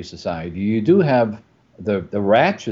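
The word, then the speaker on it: Radch: I usually say ratch, but yeah.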